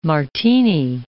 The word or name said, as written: martini